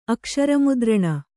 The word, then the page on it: ಅಕ್ಷರಮುದ್ರಣ: Kannada